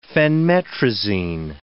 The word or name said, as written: phenmetrazine